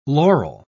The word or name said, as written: laurel